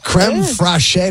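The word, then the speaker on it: crème fraîche: Creme fraiche, did I say that correctly? – Creme chives.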